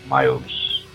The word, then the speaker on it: Miles: Eu acho que a pronúncia é Miles mesmo. – Miles. Miles. – Que nem o professor Miles Warren.